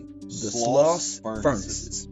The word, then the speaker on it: Sloss Furnaces: The Sloss Furnaces.